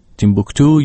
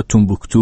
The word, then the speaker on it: تیمبوکتو: گزارش همکارم میرعلی حسینی رو درباره بحران در مالی شنیدیم و همونطور که در این گزارش هم شنیدیم شهر تاریخی تیمبوکتو یا تومبوکتو اونطوری که فرانسوی‌ها تلفظ میکنن تا به دست در واقع شورشی‌ها افتاده.